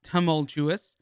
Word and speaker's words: tumultuous: We learned that the word is pronounced tumultuous. The most tumultuous.